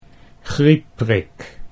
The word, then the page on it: griepprik: Dutch